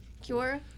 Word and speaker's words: Quora: And I found four of them on Cura. I think I'm saying that right. Cura? Cura? – Quora. – Quora. Quora.